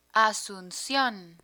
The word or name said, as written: asuncion